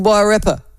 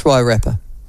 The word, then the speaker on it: Wairarapa: And you keep on pronouncing Wairarapa. Sounds kind of, it's kind of like pronouncing Cromwell's Cremwell. Wairarapa. I'm not going to do that. It's Wairarapa.